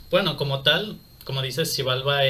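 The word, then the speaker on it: Xibalba: Y como tal no era es bueno, según muchos lo pronuncian como Chivalba, otros dicen que es Chivalba. La cosa es que como tal se ha perdido mucho de todo eso. Ah, situación. no soy maya, no puedo yo decir. – yo, lo pronuncio como Shivalba.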